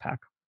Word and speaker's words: Pak: His name gets spelled both ways, but I believe it's pronounced Pak.